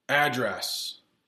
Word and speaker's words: address: But if you want to use that same word as a verb, you have to change the pronunciation and you have to change it to address.